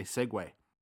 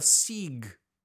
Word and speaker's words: segue: I had no idea that I was saying it wrong. I said it wrong until I was in my 30s. Until someone corrected me. – Into your 30s? – Yeah. – Wow. – I was an editor for regional newspapers. I was working for CBC Radio. I was a professional writer, and I was still pronouncing it sieg.